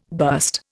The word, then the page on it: burst: Indian